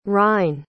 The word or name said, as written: ryne